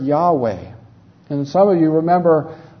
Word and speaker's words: Yahweh: But the Hebrew word is something like Yahweh. There's some doubt about how it's pronounced, but that's the way most people say it, so that's how we'll say it.